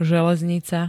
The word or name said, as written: železnica